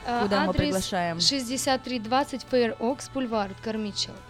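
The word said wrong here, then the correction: Carmichael: правильно говорю? – Кармайкл, да, Кармайкл.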